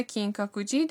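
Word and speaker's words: 金閣寺: اگر بخواید بگید که دوست دارم به معبد مشهور کینکاکوجی در شهر کیوتو برم چه میگید؟ تلفظ این معبد هست کینکاکوجی.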